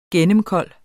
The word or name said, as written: gennemkold